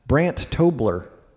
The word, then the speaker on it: Brandt Tobler: Funny Brandt Tobler.